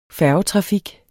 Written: færgetrafik

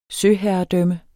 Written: søherredømme

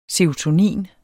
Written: SEROTONIN

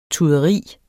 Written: tuderi

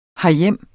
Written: herhjem

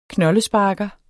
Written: knoldesparker

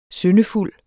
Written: syndefuld

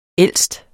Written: ældste

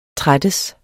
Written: trættes

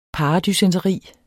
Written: paradysenteri

